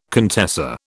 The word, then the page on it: contessa: British English